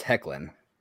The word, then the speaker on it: Hoechlin: Yeah, it's pronounced Hecklin.